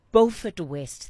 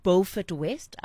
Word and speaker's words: Beaufort West: All right, we're getting then into round two of the Know Your Candidates series, and we are in Beaufort West. Thank you to our listener that has sent me a correction. Beaufort West, I hope I'm getting it right this time.